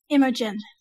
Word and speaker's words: Imogen: Is it Imogen or Imogen? – Imogen.